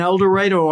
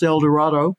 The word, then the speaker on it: El Dorado: And I did a program in El Dorado, Arkansas, which would in any other state would be pronounced El Dorado.